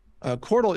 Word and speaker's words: Qortal: Qortal is a decentralized platform for content distribution.